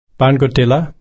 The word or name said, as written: barnguttilla